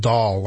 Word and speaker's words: Doll: And Elizabeth in the chat room says it's pronounced doll, like in doll. – Yeah, doll. So it's Jim Doll.